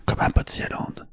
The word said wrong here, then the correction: Plopsaland: Ah, Plopsaland. Ouais, Plopsaland, j'avais du mal à prononcer, ce que je l'ai jamais dit avant.